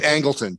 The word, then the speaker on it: Angleton: I mean, you've got the James Jesus Angleton. Is that how you say it? – Angleton.